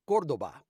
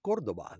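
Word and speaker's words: Córdoba: The word is actually pronounced Cordoba, because it's from Spain. And for some reason, Chrysler wanted to Americanize it, and that must have been painful for him. I think he is from Spain, to say Cordoba, because it's actually Cordoba. The accent is on the first O.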